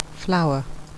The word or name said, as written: flour